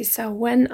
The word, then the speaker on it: Samhain: Warum hat sie Samhain ausgesprochen und nicht das Wort, das ich da gerade lese? Geschrieben wird Samhain Samhain. Doch das Fest ist ein altes keltisches Fest, das ihren Ursprung ja ungefähr im 8., 9. Jahrhundert hat. So genau kann man das nie sagen bei rituellen, zeremoniellen Festen. Und ja, im Keltisch-Irischen spricht man oft die Worte nicht so aus, wie man sie liest.